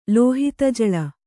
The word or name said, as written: ಲೋಹಿತಜಳ